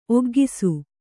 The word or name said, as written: ಒಗ್ಗಿಸು